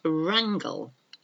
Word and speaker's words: wrangle: So the first one is wrangle. Now, this is spelt W-R-A-N-G-L-E. I'm going to wrangle this off you. I'm going to fight you for it. Wrangle. Wrangle, that's one meaning of the word. Wrap, better wrap up warm today, it's cold out. W-R-A-P, wrap.